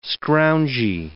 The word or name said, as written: scroungy